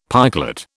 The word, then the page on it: pikelet: British English